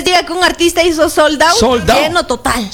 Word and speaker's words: sold out: Soldado. – Sold out. – ¿No es soldado? – No. – Yo es que pensé que se pronunciaba soldado. – No, hombre, ¿cómo se va a pronunciar soldado? – Soldado.